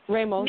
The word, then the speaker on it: Ramos: Did I say that right? Ramos or Nicky Jam? – Ramos.